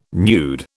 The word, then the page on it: nude: British English